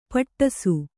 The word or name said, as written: ಪಟ್ಟಿಸ